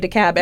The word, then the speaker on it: DeKalb: It's a silent L? – You throw that L away. – Mm-hmm. Okay, forget I said that. It's obviously DeKalb.